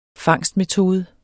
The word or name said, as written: fangstmetode